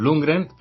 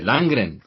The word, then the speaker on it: Lundgren: Bueno, pues sí, desde luego Lord Lundgren, pues se pronuncia igual que en español, aunque aquí estamos acostumbrados a llamarle Langren.